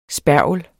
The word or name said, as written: spergel